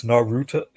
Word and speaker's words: Naruto: It's, it's a little bit off. It's Naruto. It's it sounds like you said it, but it's okay. softer. it's a softer.